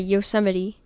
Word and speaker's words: Yosemite: I haven't been to Yosemite. Or sorry, Yosemite. I don't know why. – I get it because it's spelled like that.